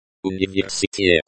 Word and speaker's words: университет: Университет.